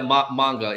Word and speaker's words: manga: What's a Mongu? Mongu? Explain that to me. – Yeah, manga.